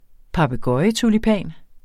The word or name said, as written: papegøjetulipan